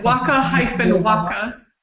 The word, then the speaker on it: WakaWaka: Yes, waka-waka.